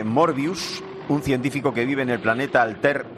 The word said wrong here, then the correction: Altair: 4 con Altair. – Su hija. Altair. Pero se pronuncia en el idioma de Alter, se pronuncia Altair.